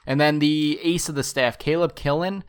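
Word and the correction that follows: Kilian: I believe that's how you pronounce his name. He's 13 starts. Killian, thank you. That sounds better.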